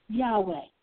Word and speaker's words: Yahweh: God's name is always translated Lord, L-O-R-D, all caps, in the English Bible. But the Hebrew would be pronounced something like Yahweh.